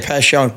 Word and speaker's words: Passyunk: Passyunk? Is that how you pronounce it? – Yeah, Passyunk.